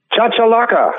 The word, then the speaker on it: chachalaca: Is it Cacalacus you're going down in Texas to go hunting next week? – Chachalaca. – Chachalaca.